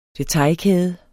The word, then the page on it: detailkæde: deˈtɑjl-; deˈtɑj-